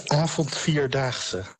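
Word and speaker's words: Avondvierdaagse: Is that how you pronounce the festival? – The Avant-Vier Daags. – Yes.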